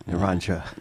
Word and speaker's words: Naranga: it's Naranga. Is that proper? – It's Am I saying it properly? – We say Naranga. – Naranja.